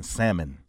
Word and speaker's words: salmon: This particular podcast, since I'm on it, this for all the women that pronounce the L in salmon.